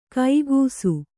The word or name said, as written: ಕೈಗೂಸು